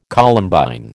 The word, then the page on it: Columbine: American English